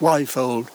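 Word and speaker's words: Quayfold: Her name was Martha Hart, and she was born in a house called Wyfold, spelt Q-U-A-Y-fold, Keyfold or Quifold, but pronounced Wyfold.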